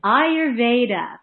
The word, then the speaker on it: Ayurveda: So how to pronounce? Let's start off. It's Ayurveda. Okay. And so the British pronunciation is Ayurveda. So either one is acceptable, but Ayurveda is how we pronounce it.